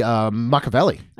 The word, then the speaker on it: Machiavelli: It should be Machiavelli.